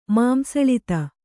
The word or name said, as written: ಮಾಂಸಳಿತ